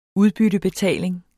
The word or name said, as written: udbyttebetaling